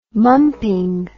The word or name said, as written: mumping